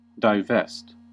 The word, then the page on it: divest: American English